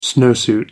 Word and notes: snowsuit: American English

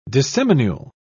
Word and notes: disseminule: British English

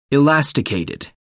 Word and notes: elasticated: American English

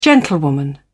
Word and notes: gentlewoman: British English

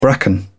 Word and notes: bracken: British English

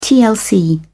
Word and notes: tlc: British English

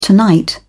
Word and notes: tonight: British English

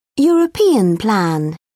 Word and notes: european plan: British English

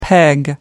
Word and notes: peg down: British English

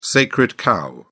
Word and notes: sacred cow: British English

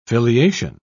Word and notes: filiation: British English

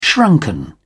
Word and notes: shrunken: British English

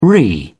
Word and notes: re: British English

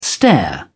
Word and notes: stair: British English

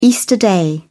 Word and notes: easter day: British English

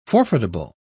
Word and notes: forfeitable: British English

